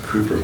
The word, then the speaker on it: Cowper: It's spelled Cowper, but it's spelled C-O-W-P-E-R, but it's pronounced Cooper, William Cooper.